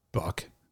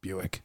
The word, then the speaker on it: Buick: We don't say Buck. It's Buick. There's just certain things you're just going to have to learn to pronounce.